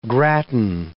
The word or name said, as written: gratin